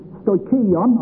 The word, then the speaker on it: stoicheion: That word, first principles, is the Greek word, not that Greek words mean much to us, stoikion, I think you pronounce it, S-T-O-I-C-H-E-I-O-N is how it's spelled in English.